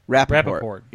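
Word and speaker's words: Rapoport: Rappaport.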